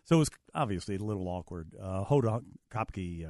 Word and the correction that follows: Kotb: Kotb, not Hopke. What is it? Kotb. Kotb? K-O-T-B.